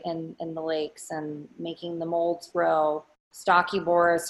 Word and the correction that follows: Stachybotrys: I always say it wrong. What's wrong with me, man? What is it? Stinky butt ass? – Stachybotrys. – Stachybotrys. There you go.